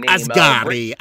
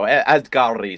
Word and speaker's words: Asghari: Asghari. – There we go. Asghari.